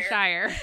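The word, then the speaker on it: Shirer: Say her name for me, please. – Priscilla Shire. – Shire. – Yeah, you're good.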